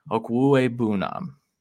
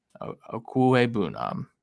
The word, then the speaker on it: Okwuegbunam: He has the pronunciation in his Twitter, so if you ever feel like you need help with this, he's got it covered. It's Okwuegbunam. Okwue Bunam. And I've never said it like that.